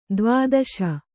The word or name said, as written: ದ್ವಾದಶ